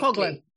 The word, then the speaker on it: Coghlan: So with COVID changing our lives drastically, and this, of course, flows through to writing exams, you co-authored a paper with Simon Colan and Tim Miller titled Good Proctor or Big Brother, Ethics of Online Exam Supervision Technologies. Tell us about this. Did I pronounce Simon's surname correctly? – Coghlan.